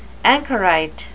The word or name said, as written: anchorite